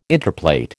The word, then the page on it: interplait: American English